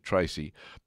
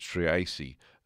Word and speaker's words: Treacy: It's spelled T-R-E-A-C-Y, but I hear the broadcasters announcing it Tracy, but it's Triasi, actually, and 5 singles.